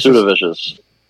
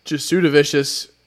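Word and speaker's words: Jasudavicius: Jows DeVicious, I believe. Is that how you say his name? Or Joe's DeVicious? – Jasudavicious. – Jasudavicious, very vicious, is fighting Natalia Silva.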